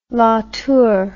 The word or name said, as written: latour